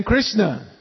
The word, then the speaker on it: Krishna: And then Krishna. Everybody say Krishna. Krishna, only two syllables.